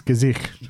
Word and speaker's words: Gesicht: Also, not to be that guy, but you mispronounced the main character's name a few times. It's Gesicht.